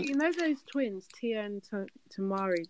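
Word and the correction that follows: Tamera: Did I say it right? – Tia and Tamira, yeah.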